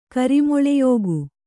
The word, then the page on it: ಕರಿಮೊಳೆಯೋಗು: kannada